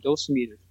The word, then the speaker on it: dosimeters: I think they call them dosimeters, is the way I heard it pronounced.